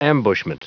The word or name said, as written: ambushment